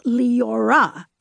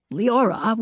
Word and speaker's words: Leora: Position your tongue for an L, O. Then say yaw, then say va, yaw va. In high school, I changed the V to R, and Leora, pronounced Leora, was born, hence Lee.